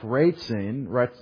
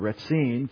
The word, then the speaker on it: Rezin: So the summary of the action, they came up, Ratzin is how you say it in Hebrew, Ratzin,